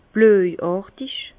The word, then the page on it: blöjàrtig: Bas Rhin; Herrlisheim